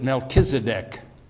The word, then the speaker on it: Melchizedek: We pronounce it Melchizedek.